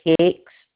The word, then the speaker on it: Hix: The last name's Hicks. It's spelled H-I-X.